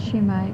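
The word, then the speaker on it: sumac: A bush called some people say that it's poison, called shumac. – Yes. – Is that how would you say that? – That's what it is, shumac.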